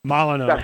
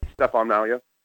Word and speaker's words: Molyneux: Stephen Molyneux. I always get his name wrong. – Molyneux. – Stefan Molyneux. – Stefan Molyneux. – Molyneux, yeah.